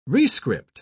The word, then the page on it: rescript: British English